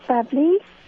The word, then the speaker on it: Babli: My name is Bapli. – Could you spell it? – B-A-B-L-I.